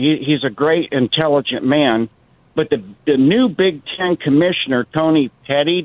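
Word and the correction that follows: Petitti: did I say his name right? – Petiti. – Say it again. – Petiti.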